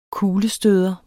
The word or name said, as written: kuglestøder